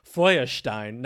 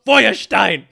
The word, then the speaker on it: Feuerstein: In response to this minimalist cup, conservative Christian internet evangelist Joshua Feuerstein, now you probably don't pronounce it like that. – Probably not. – When you pronounce it in the proper German way, it sounds right.